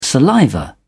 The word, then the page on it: saliva: British English